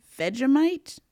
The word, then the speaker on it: Vegemite: He has her try Vegemite.